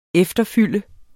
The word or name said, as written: efterfylde